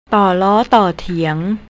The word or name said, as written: torlor